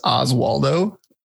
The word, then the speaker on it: Oswaldo: So my name is pronounced Oswaldo.